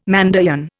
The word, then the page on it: Mandaean: Indian